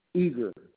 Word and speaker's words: Eager: James Eager, E-A-G-E-R.